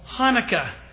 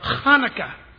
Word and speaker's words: Hanukkah: That is Cheslev 25th, or what we call Hanukkah, or Hanukkah. You got to spit when you say it if you say it right.